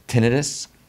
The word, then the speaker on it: tinnitus: Tinnitus? You know that's the correct way to say it? – Tinnitus? – Yeah.